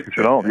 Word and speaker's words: Shenault: You told us you were the one that turned us on to that, that great receiver, Shenault. Is that how you say it? I well, mean, Shenault, yeah.